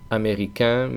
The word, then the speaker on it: américain: Primero, los que acaban en ain, que se pronunciará en el masculino. Por ejemplo, américain, mexicain, africain, marroquín, cubain.